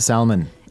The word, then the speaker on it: salmon: First of all, salmon is spelled S-A-L-M-O-N. – Right, and you do not pronounce the L. It's not salmon. – It's not salmon. – It's salmon.